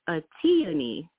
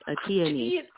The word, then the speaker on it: Atiyani: Yeah, my name, you're doing really well, and even better is Atiyani, like a peony, Atiyani.